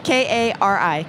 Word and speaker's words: Kari: K A R I, Kari Bristow, B R I S T O W.